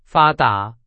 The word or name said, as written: 发达